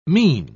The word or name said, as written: mesne